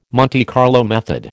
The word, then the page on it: Monte Carlo method: American English